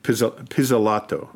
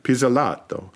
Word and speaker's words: Pizzolatto: It's written by novelist Nick, I want to say his name right, Pizzolatto, very Italian, Pizzolatto.